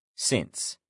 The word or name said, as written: since